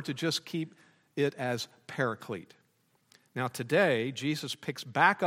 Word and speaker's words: paraclete: We did point out last time that the Greek term translated helper is what we pronounce paraclete.